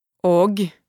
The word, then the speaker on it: og: Og means and. Try not to pronounce G in a hard way. Soften it. Og.